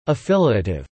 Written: affiliative

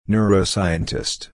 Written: neuroscientist

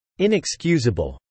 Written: inexcusable

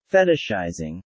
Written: fetishizing

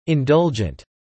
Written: indulgent

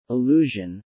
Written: allusion

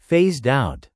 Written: phased out

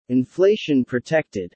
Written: inflation-protected

